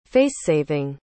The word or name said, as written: facesaving